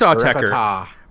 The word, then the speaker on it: Autechre: And then coming in last was Autechers, Try Repite, maybe. I don't know. That's how you... I think it's Autechre. – Repita. – I think Autechre is how you pronounce the band name.